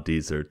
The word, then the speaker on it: Deezer: This podcast is on Deezer too. – Whoa! – How do you spell it? – It's like Weezer, but with a D.